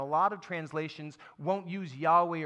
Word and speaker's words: Yahweh: And that word I am is in Hebrew is pronounced Yahweh.